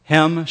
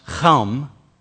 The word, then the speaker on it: Chem: Now, you remember that Noah had three sons, Hem, Shem, and Japheth? Hem was actually pronounced Chem, and it meant one born in the tropics, son born of heat.